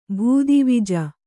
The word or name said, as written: ಭೂದಿವಿಜ